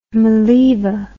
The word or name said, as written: Maleeva